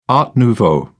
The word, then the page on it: art nouveau: British English